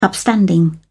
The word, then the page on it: upstanding: British English